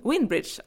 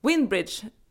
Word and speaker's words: Windbridge: Jag har sagt Winebridge, alltså vinbro, för att be om ursäkt om jag säger fel. Det är förstås vindbro. Windbridge. Alltså, hjärnan funkar ju inte alltid. Windbridge, så ska man uttala det.